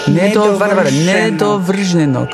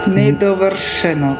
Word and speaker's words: nedovršenog: Ja, nedovrš. Also nicht sch, sondern sch. Nedovršenog. – Nedovršenog. – Ja. – Und das G spricht man nicht? – Hä? – Das G spricht man gar nicht? – Ja, doch. So, nedovršenog.